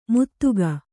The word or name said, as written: ಮುದುಕ